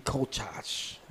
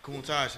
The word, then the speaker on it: Countach: Lamborghini Countach. I ain't even pronouncing that right. – Countach, 95.